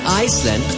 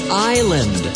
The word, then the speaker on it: island: Not Iceland, island.